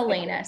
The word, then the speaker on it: Alaina: Is it Elena? – Yeah, it's okay. Elena. So because I knew I wanted to take it the Irish route, I originally had Elena spelled with more of like, I guess it's like a Spanish influence, but Elena spelled under like the Irish influence is Alaina.